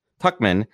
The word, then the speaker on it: Tuchman: And then, as I mentioned earlier, welcome two incredible Miami-based tech founders, Brian Brackeen and Max Tushman. Tushman, how does she pronounce her name? How does Max pronounce it? Tuckman, yes, for a panel discussion on all things Miami Tech.